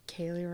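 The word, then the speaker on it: Kali: You can find me on Twitter at, I think it's Kaylee Renee or something like that, or Kaylee Redburn.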